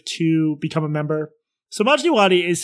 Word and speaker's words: Majdi Wadi: It's called Honestly with Barry Weiss, and her first interview was with a guy named Majid Wadi. Majid Wadi? How do you pronounce it? – Majdi Wadi. Yes.